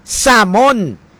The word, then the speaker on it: salmon: Balo kamo sang isda nga salmon, kun sa Ingles ang panawag salmon, silent L, S-A-L-M-O-N. Sa aton ti panawag ta salmon, ang gamay ti salmoniti, paborito ko nga pinamalan.